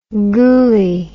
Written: goolie

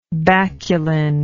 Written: baculine

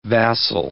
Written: vassal

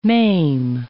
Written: maim

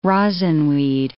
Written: rosinweed

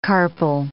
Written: carpel

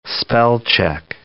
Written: spell-check

